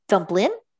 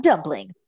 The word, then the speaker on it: Dumplin: So my next book is Dumplin. And yes, I said that correctly. Not Dumpling, but Dumplin by Julie Murphy.